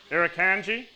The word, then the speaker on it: Irukandji: There's Irin Jansey. Don't know if I pronounced that correctly, but... They're very. – I'm not asking Japanese ladies. I think that's a Japanese term. Irukandji.